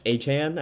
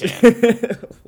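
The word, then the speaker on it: Achane: Achan, I think, is what he wants. – Achan.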